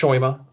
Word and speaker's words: shomer: Our movie opens up with some text on screen kind of describing what a shoimer is, spelled S-H-O-M-E-R, pronounced shoimer.